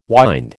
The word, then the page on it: wynd: American English